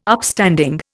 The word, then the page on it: Upstanding: Indian